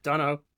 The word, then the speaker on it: Donahoe: Yeah, Donahoe.